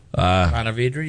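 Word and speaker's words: Ponte Vedra: Is that how you say it? Is it Pontevedra Beach, Florida? Anyway. – Sure. – Pontevedra, yeah.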